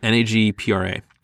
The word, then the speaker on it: NAGPRA: Yeah, I think that's how you say it, NAGPRA. – Yeah, NAGPRA.